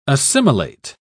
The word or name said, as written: assimilate